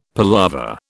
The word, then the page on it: palaver: British English